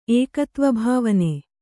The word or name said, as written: ಏಕತ್ವಭಾವನೆ